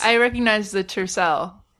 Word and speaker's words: tiercel: Dad is called a Tiercel. T-I-E-R. Tier. C-E-L. Cell.